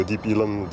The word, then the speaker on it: Deep Ellum: Deep Elm. Deep Elm, c'est une façon de prononcer euh, Street, Deep Elm Street.